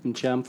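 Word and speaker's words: Ntcham: Incham. Forget the T.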